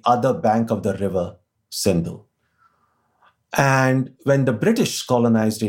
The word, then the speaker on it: Sindhu: The original name of that river is Sindhu. Now, ancient Persians, whenever they had this word with the letter S, they tended to pronounce it with an H sound. So they pronounced the name of the river as Hindu.